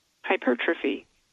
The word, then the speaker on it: hypertrophy: Hypertrophy.